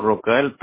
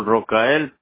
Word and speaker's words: Rocael: Es Hugo Rocael Pérez de León. – Ah, ya se me adelantó un poquito. ¿Hugo cómo? – Hugo Rocael Pérez de León. – Rocael. Y Rocael se escribe R-O-C.